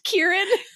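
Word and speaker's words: Ciarán: We've been saying that the ass butthole wipe that is Syrian Hines. – Sorry. – His name is actually pronounced Kieran.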